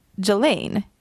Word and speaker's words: Ghislaine: Jag är fortfarande chockad över att Ghislaine uttalas Ghislaine. – Jag vet. – Så konstigt. – I mean, don't fact check me on that, men jag tror att det är Ghislaine.